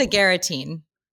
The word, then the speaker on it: agaritine: The first one is, Should I be concerned about agaritine? Did I pronounce that right? – I think it's agaritine.